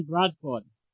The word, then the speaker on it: Bradford: This is Don Bradford. – Would you spell that for me, please? – Don Bradford. D-O-N-B-R-A-D-F-O-R-D. – D-O-N-B-R-A-D-F-O-R-D.